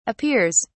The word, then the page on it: appears: British English